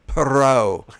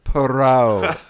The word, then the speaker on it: Poirot: Am I pronouncing that right? – Poirot. – How is it again? – Perot.